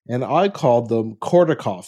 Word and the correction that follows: Korotkoff: They are called Korotkoff sounds, not Kordakoff.